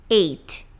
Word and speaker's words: eight: Agora, o número em inglês, ele se pronuncia t. Eight. Eight.